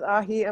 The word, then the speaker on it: aji: Not A-H-I. A-J-I. Aji. Maybe you'd pronounce it. Not aji. Aji. Amarillo. A-J-I. And then the second word is A-M-A-R-I-L-L-O.